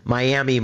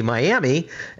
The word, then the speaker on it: Miami: So I picked Miami, Oklahoma, which coincidentally it's spelled like Miami. Well, when those Native Americans were in the Carolinas, and when they were marched off of their land, a few of them ended up in Florida, pronounced Miami, Miami, and a lot of them ended up in Oklahoma, where Miami became Miami.